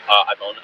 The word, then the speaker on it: Ivon: My name is Cameron, K-A-M-R-I-N. – Okay, last name? – Ivone, I-V-O-N.